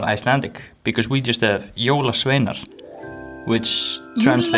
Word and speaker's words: Jólasveinar: So after telling you everything about Icelandic Christmas traditions last week, we are finally going into the Jóla Sveinir. Did I say that right? – Jóla Sveinar. – Sveinar.